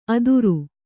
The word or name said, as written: ಅದುರು